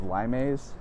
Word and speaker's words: Limes: There's a supplement a supplement I think called Limes, and um, looks like the word looks like limes, but it's Latin, so you would pronounce the e.